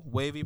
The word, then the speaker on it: Wavey: So the Wavey podcast, it is the IG is official Wavey Productions _. Now that's Wavey with the E. Okay. – It's a silent E, but it's an E. – Yeah. It's an E in there and then a Y.